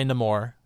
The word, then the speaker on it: Namor: But I'm gonna say Namor because that's how Namor pronounces it, just like Shang-Chi.